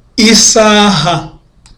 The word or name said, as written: isaha